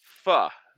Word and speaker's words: pho: No, like pho is the correct way of pronouncing it, right? P-H-O.